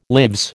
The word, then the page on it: lives: American English